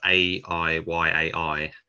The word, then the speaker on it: I I: Um, uh, I I is, uh, which is pronounced A I Y A I.